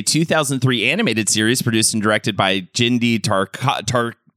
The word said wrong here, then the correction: Tartakovsky: Tartakovsky. That's, oh man, I said that right.